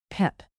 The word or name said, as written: pep